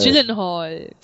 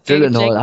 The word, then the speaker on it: Gyllenhaal: Ja, Jake Gyllenhaal. – Gyllenhaal. – Gyllenhaal, habe ich schon gesagt.